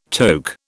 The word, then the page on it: toque: British English